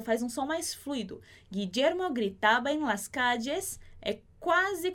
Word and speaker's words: Guillermo: Aqui aquele som do G, né? Como se fosse em gato. Gui. Guillermo, igual em português. Gui de Guilherme, gui de Guillermo.